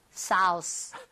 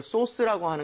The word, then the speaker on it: sauce: AU라는 스펠링 보이시죠? – 예. – AU나 AW 스펠링이 나오면 O보다 조금만 입모양을 벌려준다. – 아. – 이 느낌. 그래서 소스보다는요. 소스. 소스. 살짝만 벌리시면 되거든요.